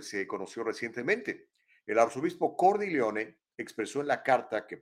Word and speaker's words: Cordileone: El arzobispo de San Francisco, que se llama Salvatore Cordileone, o para decirlo como se pronuncia en italiano, Salvatore Cordileone,